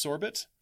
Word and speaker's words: sorbet: It's actually pronounced Sorbet. It's Sorbet. You know, okay. That's how Americans say it.